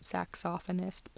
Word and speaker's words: saxophonist: Juliet Jackson exchanged ogles with Jimmy Mitchell, star saxophonist of the collegians. By the way, my husband says that should be pronounced saxophonist, but I'm not.